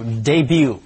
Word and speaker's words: debut: 我们接下来看这个字 debut，这个字要读 debut，后面的 T 不发音，因此它的过去式要读 debuted，进行式要读 debuting，复数要读 debuts，所有的 T 都不发音。